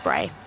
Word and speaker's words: Brae: Bray.